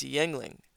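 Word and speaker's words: Yuengling: The correct pronunciation is Yengling.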